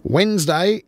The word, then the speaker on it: Wednesday: Wednesday is not is Wednesday, not Wednesday. So, yes, the D is in there.